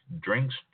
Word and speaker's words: Drinjk: D-R-I-N-J-K. So if the J is silent, it's pronounced Drink.